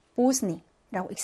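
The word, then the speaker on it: 보스니 (поздний): 그다음 늦은이라는 말도 보시면 자음 세 개가 나란히 왔는데 가운데 대발음이 소리가 안 나요. 보스니라고 읽습니다.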